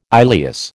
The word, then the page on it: ileus: American English